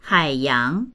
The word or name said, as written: hai3yang2